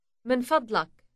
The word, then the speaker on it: من فضلك: من فضلك من فضلك Note the uk ending. Say please once more. – من فضلك.